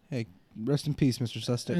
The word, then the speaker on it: Sustek: Scott and then S-U-S-T-E-K. – Oh. – Scott Sustic. Sustek. Yeah, Sustek.